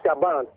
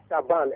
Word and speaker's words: Shaban: I hope I pronounced your name correctly. – I'm Alpha Shaban. – Shaban, right. – Alpha Shaban, S-H-A-B-A-N.